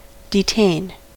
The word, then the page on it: detain: American English